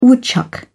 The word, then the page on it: woodchuck: British English